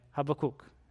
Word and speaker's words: Habakkuk: Habakkuk is how you would pronounce that.